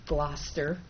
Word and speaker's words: Gloucester: Because I saw that thing in Gloucester County. I said it right this time.